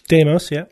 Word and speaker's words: Deimos: Mars has two small moons, Phobos and Deimos. Is that how you say it? – Deimos, yeah.